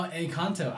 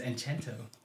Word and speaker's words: Encanto: Oh, Encanto. I thought it was Enchanto.